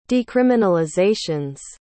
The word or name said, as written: decriminalizations